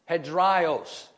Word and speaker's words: hedraios: Phonetically, that word is spelled H-E-D-R-A-I-O-S, hedraios, hedraios.